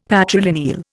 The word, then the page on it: patrilineal: Indian